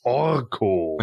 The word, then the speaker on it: Orko: Deswegen habe ich ihn auch so wie im Hörspiel ausgesprochen. Orko.